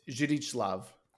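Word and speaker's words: Zdzisław: It's actually, he's Polish, so that's actually pronounced with a V. It's Zdzislaw.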